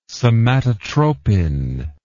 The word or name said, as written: somatotropin